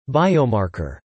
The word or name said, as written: biomarker